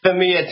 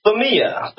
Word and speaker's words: Femia: It's Femia. – Femia. Like family. – Exactly. Like family.